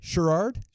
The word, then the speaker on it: Sheard: S-H-E-A-R-D. Sheard. I said it right. Sheard. – I think you threw like an extra R somewhere.